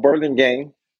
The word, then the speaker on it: Burlingame: Now, he started off as a firefighter paramedic with Burlingame Fire Department in 1997. Am I saying that right? – That's correct. Burlingame. Good old Burlingame.